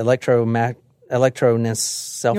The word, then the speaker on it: Electroencephalogram: They hooked him up to an alcohol electrosynlophagram. Basically, that measures brain waves. I can't pronounce it. Electroencephalogram.